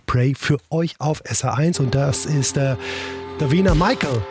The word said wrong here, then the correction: Davina Michelle: Davina Michelle, Entschuldigung, so spricht man sie aus.